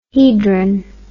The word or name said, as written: hedron